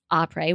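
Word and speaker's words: Après: That's D-R-I-N-K, drink, A-P-R-E-S, Opry with a silent S, .com.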